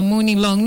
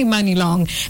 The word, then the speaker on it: Mani Long: Long, for you who met for me, I have had quite a number of people call her Moni Long. No, it's actually Mani Long. That is the correct pronunciation.